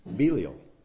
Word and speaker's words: Belial: I said Belial. Is that right? How do you say it? Thank you. That's the correct way to say it.